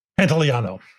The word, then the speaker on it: Pantoliano: Pantoliano.